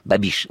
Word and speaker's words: Babiš: Dans le monde, le premier ministre tchèque, Andrej Babiš, on dit Babiš, c'est ça? – Babiš. – Ça s'écrit B-A-B-I-S, on dit Babiš.